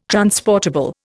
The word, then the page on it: transportable: Indian